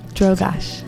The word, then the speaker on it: Drogosch: Drogash.